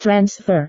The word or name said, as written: transfer